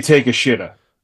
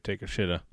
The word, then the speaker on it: Takeshita: How can you not say Takeshita? – I was going to, but I didn't want to butcher it that bad. – No, but like it's a tough one, dude. – I mean, it is spelled out right there for you, Takeshita.